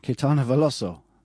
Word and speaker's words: Caetano Veloso: Caetano Veloso.